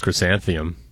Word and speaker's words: chrysanthemum: Kind of sounded like you just pronounced chrysanthemum. – Yeah, chrysanthemum. – Chrysanthemum. We know it ends. It starts with Chris, and it ends with mum.